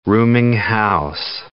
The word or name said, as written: rooming house